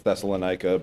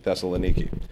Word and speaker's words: Thessaloniki: That's how you would pronounce that. Everybody says it's Thessalonica, but it's actually Thessaloniki.